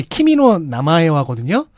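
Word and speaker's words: 키미노 나마에와거든요 (君の名は): 그거의 일본어 제목 그 발음이 키미노 나마에와거든요.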